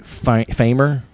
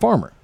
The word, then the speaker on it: Phamer: It's spelled P-H-A-M-E-R, like Famer, Famer, Famer. – Farmer? – But it's pronounced Farmer.